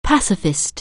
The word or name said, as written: pacifist